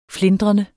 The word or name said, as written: flintrende